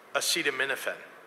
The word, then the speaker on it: acetaminophen: If you're pregnant and your doctor recommends acetaminophen, let me repeat that word for anybody who doesn't know how to pronounce it.